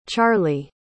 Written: charley

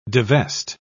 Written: divest

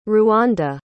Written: ruanda

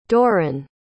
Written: doran

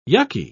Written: yucky

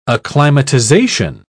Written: acclimatization

